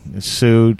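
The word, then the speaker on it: Sioux: Of course, it's spelled S-I-O-U-X, I believe, but Sue to yes.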